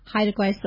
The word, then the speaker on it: Haida Gwaii: Haida Gwaii Safaris.